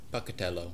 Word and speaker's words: Pocatello: I can't really pronounce this. It's P-O-C-A-T-E-L-L-O. – Pocatello. – Pocatello? Thank you.